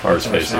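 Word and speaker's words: Arseface: Or Assface, I'm not actually sure how to pronounce that. – Arsface, yes. – Well, some people have said that they don't pronounce the R, so it might just be Assface.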